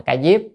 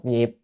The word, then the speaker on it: Ca Diếp: À, Tôn giả Ca Diếp, chữ Diếp hay Diệp vậy, nhưng mà chúng ta hay đọc là Ca Diếp nhé, hoặc đọc Diệp.